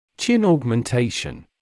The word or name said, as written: chin augmentation